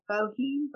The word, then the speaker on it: Boehm: Diann, D-I-A-N-N, my maiden name, Floyd, F-L-O-Y-D, B-O-E-H-M. It looks like Boehm, but we pronounce it Boehm.